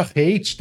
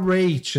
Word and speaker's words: rate: Que eu devia pronunciar rate, na verdade, né? Porque não é hate de ódio, é rate. Hate parece ódio, é, é rate.